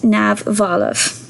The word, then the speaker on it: neamhbhalbh: you spell it n e a m h b h a l b h uh, and you pronounce it nav Valov so there we have it.